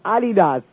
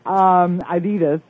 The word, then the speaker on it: Adidas: You wrote an article about Adidas, since that's how they pronounce it in Germany. Adidas. Adidas, as we say in America.